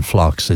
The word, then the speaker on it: phlox: Yeah, the P is silent in phlox. It's P-H-L-O-X.